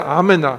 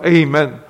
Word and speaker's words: 阿们: 那这个阿们、啊、它的正确的发音、啊、还是、啊、我们中文发的比较准、啊、阿们、啊、所以、啊、我们不一定要学、啊、用英文上学的要把这个说 Amen、啊、这个我们中文已经把它已经翻得够准确了。